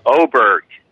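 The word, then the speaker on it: Åberg: Ludvig Adberg, Brooks Koepka. – Hold on a sec. Wait. Since we're using it's Oberg. It's Oberg.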